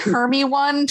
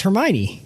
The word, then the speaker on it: Hermione: It's four syllables. Hermione.